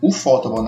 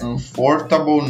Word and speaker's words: Ufotable: Não, tô falando certo, Ufotable, não é Unfortable nem Unfort, sei lá.